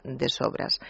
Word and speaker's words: de sobres: porque en catalán se dice de sobras, escrito de sobres, pero se pronuncia de sobras.